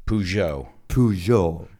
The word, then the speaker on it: Peugeot: Porque, ¿cómo decís, por ejemplo, vosotros Peugeot? – Peugeot. Yeah. – Like, yeah. – Kind of French, right? – Peugeot.